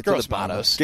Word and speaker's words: Gross-Matos: Tell me how to say his name, finally. – Yeah, it's Gross-Matos.